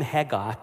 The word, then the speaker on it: Haggai: The name Haggai is pronounced in Hebrew Haggai. Okay, that H is a H. The Scots and the Afrikaners can do it well.